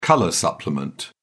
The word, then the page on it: colour supplement: British English